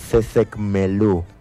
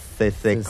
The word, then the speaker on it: sesecmelú: Y en lengua yocotán se pronuncia, vamos a escuchar con atención, por favor, sesecmelú. Repito nuevamente, sesecmelú.